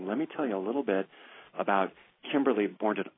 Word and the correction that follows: Bordenaro: We just had a conversation about me pronouncing her name correctly, and I just went to butcher it. Kimberly Bordenaro.